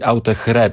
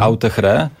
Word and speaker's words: Autechre: Zespół się nazywa Autechre, ale sami oni mówią, że pisze się dokładnie tak, jak teraz powiem, czyli Autechre przez CH. Mhm. Autechre. I sami oni mówią, że można mówić i tak, i tak.